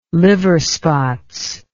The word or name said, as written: liver spots